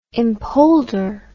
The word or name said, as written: empolder